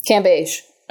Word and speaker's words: Cambage: Wait, it's Liz Cambage or is it Cambage? – Cambage. – Okay, we've been wondering this. – We need to pronounce it correctly.